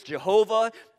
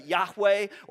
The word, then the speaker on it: Yahweh: And for years we've pronounced this Jehovah. Probably a better pronouncement, Yahweh or Yahweh would be even more. And this is a proper name.